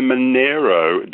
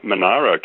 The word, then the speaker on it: Monaro: Go to the Snowy Mountains and say you're in the Monaro country, and you'll be known immediately as not a local.